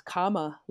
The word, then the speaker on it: Kamala: Am I pronouncing this right? – Yeah, I know. It's Kamala Harris. Kamala.